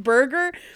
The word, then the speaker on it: Burgher: And it was actually in response to a law called the Burger Oath, which, and again, it's not spelled like burger. It's B-U-R-G-H-E-R, burger. – Wait, spell it again. – B-U-R-G-H-E-R, it's, burger.